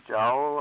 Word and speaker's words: jowl: I think you said jewel? – Jowl, all right. – Jowl? – Jowl. Is that how you pronounce it? – J-O-W-L. Spelled, I think, jowl.